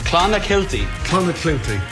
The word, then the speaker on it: Clonakilty: Clonakilty, thank you.